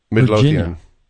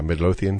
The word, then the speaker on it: Midlothian: They're in, how do you say this? It's Midlothian? – Midlothian. – Is that it? – Yeah, Midlothian, Virginia.